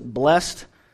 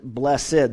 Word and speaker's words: blessed: Now, some people pronounce it blessed, and others pronounce it blessed, the old way to say it.